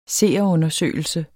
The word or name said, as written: seerundersøgelse